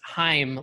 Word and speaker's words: Haim: But I said it was pronounced Haim like time. Here's why. Look, I looked it up on the internet. There was, like, Rolling Stone was like, it's like Haim, like it rhymes with time.